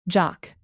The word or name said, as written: jock